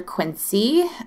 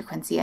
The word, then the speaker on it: Quincee: I'm at Dr. Quincee. My name is spelled weird. It's with two E's at the end. So Dr. Quincee on Instagram.